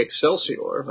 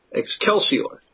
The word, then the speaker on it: Excelsior: Well, I'm saying that medieval church Latin pronunciation of this would have the C as being a sort of a CH, almost like a double C. – But we're not in a medieval church, are we? – Yeah. No, I usually would even do a very classical Roman pronunciation of this would have been with a hard C as a K, Excelsior.